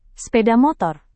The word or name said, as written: speda motor